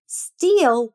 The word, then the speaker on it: steal: Steal com EA é o verbo roubar. Tem a pronúncia mais alongada, olha só. Steal. – Repeat. Steal. Steal. Still, escrito S-T-I-L-L, significa ainda.